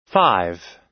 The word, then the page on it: five: American English